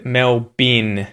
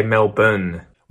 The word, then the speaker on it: Melbourne: Still not how we would pronounce it. We'd say Melbourne. – We would use the schwa for the O-U-R-N-E part of the end of the word with N at the end, right?